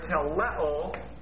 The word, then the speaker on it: teleo: Well, he translated one Greek word there, teleo. It all translates one Greek word, T-E-L-E, long O, teleo, translated in the King James as it is finished.